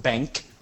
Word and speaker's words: BenQ: Anyway, I ended up buying a scanner of a brand called BenQ, which Brian says is pronounced Bank. – BenQ, whatever.